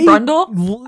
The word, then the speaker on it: Brundle: Yes, I'd like him to shoot the Trundle fly. – Uh, I Brundle. I just said it wrong.